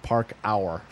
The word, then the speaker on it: parkour: Park. P-A-R-K. O-U-R. Park hour.